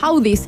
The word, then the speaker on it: Audies: existe un premio especial de audiolibros que se llama los Audis, se escribe Audies, ¿no?